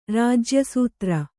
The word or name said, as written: ರಾಜ್ಯಸೂತ್ರ